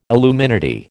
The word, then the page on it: illuminati: American English